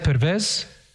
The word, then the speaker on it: Pervez: Pervez? Pervez. You have a W. That's the Turkish, they don't have a W. They say V.